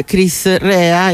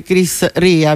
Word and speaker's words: Chris Rea: Cominciamo con un artista irlandese, ma in realtà lui è figlio di un italiano e di una irlandese, si chiama Chris Rea, io lo pronuncio all'italiana, anche se ovviamente internazionalmente è conosciuto come Chris Rea.